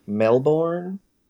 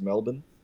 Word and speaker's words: Melbourne: Yeah, Melbourne, or as you guys might say, Melbourne. – Yeah, we don't pronounce the R, it's just Melbourne.